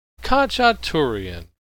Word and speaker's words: Khachaturian: Khachaturian.